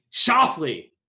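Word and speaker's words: Schauffele: Shoffley.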